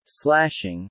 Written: slashing

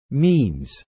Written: memes

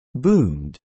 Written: boomed

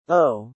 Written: owe

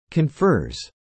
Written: confers

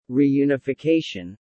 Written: reunification

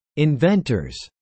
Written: inventors

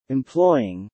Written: employing

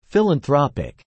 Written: philanthropic

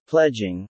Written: pledging

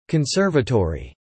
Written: Conservatory

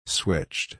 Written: switched

